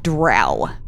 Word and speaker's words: drow: I say drow as well because that's how I've pronounced it since I started reading some certain series of books dozens of years ago. – It rhymes with cow.